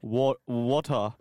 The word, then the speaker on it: water: If you want to be Australian, you need to start pronouncing ER as A. And I'm like, water.